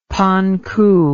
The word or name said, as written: panku